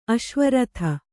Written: ಅಶ್ವರಥ

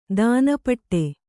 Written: ದಾನಪಟ್ಟೆ

